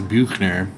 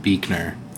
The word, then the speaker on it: Büchner: So, I've been told in German classes that it's pronounced Büchner, but my family pronounces it Büchner.